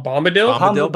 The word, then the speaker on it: Bombadil: Our next email comes in from old friend Tom Babadil. – Oh, Tom Bombadil? – Bombadil.